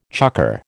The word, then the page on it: chukker: American English